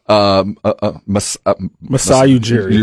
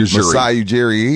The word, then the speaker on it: Masai Ujiri: Masai Ujiri. – Masai Ujiri, he's a wizard.